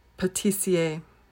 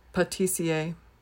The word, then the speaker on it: pâtissier: 20, now this is a French word, so you might hear the correct French pronunciation as well. I'm going to say it in an anglicized way. The male version of the word is pâtissier, pâtissier.